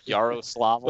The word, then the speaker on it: Yaroslavl: Yaroslavl.